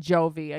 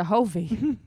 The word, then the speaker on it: Jovi: And Hovi, which also his name has never been Jovi again to me. – No, it's not. Mm-mm. It's not a real. It's not a hard J. – No, it's not. It's a Hovi. – It's a soft J.